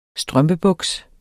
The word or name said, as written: strømpebuks